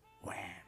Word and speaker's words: Wham!: ¿Se dice así? ¿Wham? Wham! Se dice Wham.